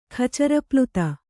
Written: ಖಚರಪ್ಲುತ

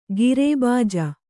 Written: ಖರಬುಜ

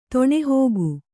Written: ತೊಣೆಹೋಗು

